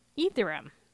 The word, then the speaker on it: Ethereum: Ethereum.